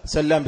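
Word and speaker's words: سلّام: مثاله هو بالمثال يتضح المقال كما يقال، سلام وسلام، سلام وسلام، سلام بتشديد اللام وسلام بتخفيفها،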